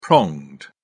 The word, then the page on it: pronged: British English